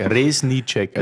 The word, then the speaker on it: Reznicek: Resnicek. Das Z spricht man aus ja, wie ein genau. S und genau. das C wie ein Ch.